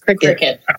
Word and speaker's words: Cricut: It's spelled C-R-I-C-U-T. So I thought it was cricket, but it's really pronounced cricket.